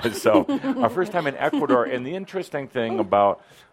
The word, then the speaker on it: Ecuador: Ecuador. They don't say D like a doorknob.